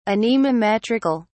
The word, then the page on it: anemometrical: British English